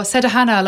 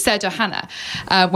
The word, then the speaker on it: Sederhana: how do you pronounce this? Like, what does this mean? He goes, oh, Sederhana, like simple. It wasn't even like, some people pronounce it Sederhana. I don't know why, but it's Sederhana, which a lot of my friends find funny because they call it, oh yeah, Simple Hana.